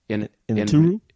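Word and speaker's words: Iñárritu: It's Alejandro Gonzalez Inarritu.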